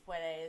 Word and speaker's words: puede: that annoys me, but the si se puede is such a... Do you... See, like, I took French and German. The D in puede, is it kind of pronounced like a TH, like a soft TH? – Yeah.